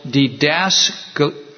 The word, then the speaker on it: didaskalia: The first of them is called didaskalia. Didaskalia. And I sort of know how to say that because I listened to it online. So I thought, how do I say this accurately? Didaskalia.